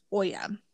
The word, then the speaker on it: Oya: Her name is pronounced Oya, and it translates as She Who Tore.